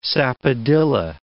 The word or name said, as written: sapodilla